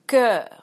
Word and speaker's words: cœur: And the sound e, when the O is attached to the E, like in sœur, cœur. Sometimes, because of two different roots, the pronunciation of O attached to E is different. It can be E, like in phoenix, fetus, and this because those two words have Greek roots. The others, cœur, sœur, have Latin roots, and we pronounce it e.